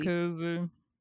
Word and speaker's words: Jacuzzi: Jossie? – Jacuzzi.